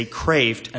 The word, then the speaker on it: Kreeft: Anyway, perhaps one question many of us have here tonight is whether the speaker's name is pronounced Kreeft or Kraft. At least it was for me. But in dealing with Dr. Kraft over the phone, I've come to hear him say Kraft a number of times, and I just sort of assume he would know.